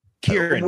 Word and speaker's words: Kieran: You mentioned Sirian Hynes. – Kieran, the way that's spelled is Kieran. Yeah. The way it's just the Irish version of spelling of Kieran.